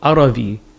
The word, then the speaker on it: Arvi: It's called Arabi, A-R-V-I. – Okay. – Because the Tamil people couldn't pronounce Arabi. – Mm-hmm. – They kept saying Arabi because they didn't have a ba.